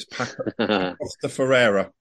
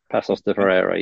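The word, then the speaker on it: Paços de Ferreira: Passos de Ferreira. – That's it. Passos, as they call it, like pronounce it. Passos de Ferreira, yeah.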